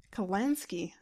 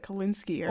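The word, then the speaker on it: Kalinske: How do you say his last name? Tom... – Kalansky? – Kalansky. Okay, I thought it was like Kalinsky or...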